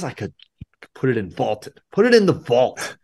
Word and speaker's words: Vaulted: That is on the Vaulted app, VLTED, pronounced vaulted, a new sports prediction app that turns your opinions into facts.